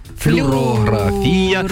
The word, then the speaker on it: флюорографія: Є один єдиний правильний наголос в цьому слові. Він на А. Флюорографія.